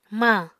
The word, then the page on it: mañ: Apatani